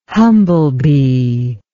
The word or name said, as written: humblebee